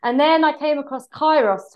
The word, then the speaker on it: Kairos: Is it Kairos? Is that how you pronounce Kairos.